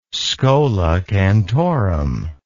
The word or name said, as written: schola cantorum